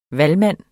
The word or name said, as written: valgmand